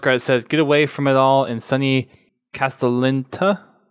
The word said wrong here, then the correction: Castellaneta: I think it's how you said it. Castellaneta. – Yeah, I think that's how it's pronounced. Castellaneta.